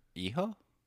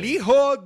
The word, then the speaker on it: Hijo: You don't pronounce the H. – All right, so you say it. – Hijo? – Okay, so Hijo del Vikingo.